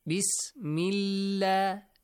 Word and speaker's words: Bismillah: But if there is a kasra sound before the word Allah, or Allahumma, it will be pronounced lightly. – Li, li. Not law. Bismillah. Bismillah. Not law. Bismillah.